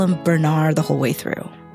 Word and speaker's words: Bernarr: And no, by the way, we are not mispronouncing his name. Bernarr MacFadden is born Bernarr MacFadden and changes his name as an adult because Bernarr sounds like a lion's roar.